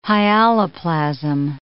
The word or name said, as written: hyaloplasm